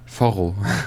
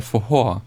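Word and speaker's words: forró: Also er wird geschrieben F-O-R-R-O oder so. – Foro. Okay. – Und man würde jetzt sagen, Foro. Ausgesprochen wird es aber Fohor.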